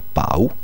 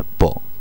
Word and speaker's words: Pau: ma mi sembra che sia Pau e non Po, come alle volte la pronuncia francese potrebbe farci pensare.